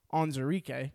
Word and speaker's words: Onwuzurike: They took arguably the best pass rushing tackle in Levi Onwuzurike. We figured out that's how you say his name this year, or this week.